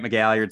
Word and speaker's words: McGalliard: My name's Grant McGalliard. Spelled like it sounds with all those vowels.